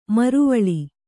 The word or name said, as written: ಮರವಾಳ್